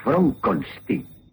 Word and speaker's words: Frankenstein: Es Frankenstein. – ¿Cómo ha dicho usted? – Mi nombre se pronuncia Frankenstein.